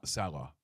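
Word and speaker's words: Saleh: Salas. – Is that right? – Salah.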